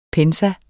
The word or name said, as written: pensum